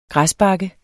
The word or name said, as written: græsbakke